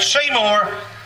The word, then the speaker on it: Seymour: You know, Clayton and Sunmore, Seymour, I didn't say it right, Seymour,